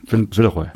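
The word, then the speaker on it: Villeroy & Boch: Das war und wie spricht man jetzt Villeroy und Boch aus? Villeroy und Boch, sagten die. Villeroy. Villeroy, genau, sagten die.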